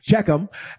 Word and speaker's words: Shechem: Joshua gathered all the tribes of Israel to Shechem. How you say it? Thank you. Shechem.